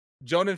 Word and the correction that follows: Joaquin: Phoenix. I definitely butchered his name. – Joaquin.